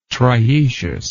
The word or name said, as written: triecious